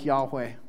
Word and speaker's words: Yahweh: In Hebrew, the name for the Lord had four letters, YHWH. And may have been pronounced something like Yahweh.